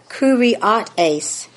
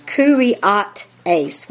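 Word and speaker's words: kyriotēs: Okay, Ephesians 1:21, number 29:63, is pronounced in the Greek kurioteis. Kurioteis, with the emphasis on the O-T.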